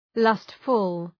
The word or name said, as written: lustful